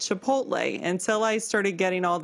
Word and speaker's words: chipotle: And I was like, huh, that L is after the T. So is it pronounced chipotle? – Chipotle. – And not chipotle, like I've been saying it.